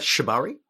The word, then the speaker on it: shibari: I've looked at how do you pronounce shibari some, Shibari, yeah.